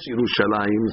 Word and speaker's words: Yerushalayim: You'll never see it, very rarely in Tanakh, with a few exceptions, is there a yud in Yerushalayim. Although we pronounce Yerushalayim, it's a kariyuktiv.